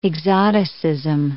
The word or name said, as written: exoticism